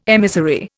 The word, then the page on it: emissary: Indian